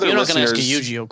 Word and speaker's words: Yu-Gi-Oh!: Apparently, I've been pronouncing it Yu-Gi-Oh! And it's Yu-Gi-Oh!